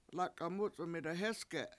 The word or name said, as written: lAk$a7 MUc$1m 7ida hEsge7